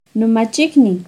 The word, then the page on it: nomachicniuh: Náhuatl